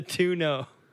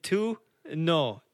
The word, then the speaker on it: Tuono: Is that how you say it? Tuno? – Yeah, the Tuno. Tuno. That's Italian for you, not...